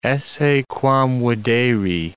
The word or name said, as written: esse quam videri